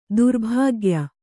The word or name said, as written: ದುರ್ಭಾಗ್ಯ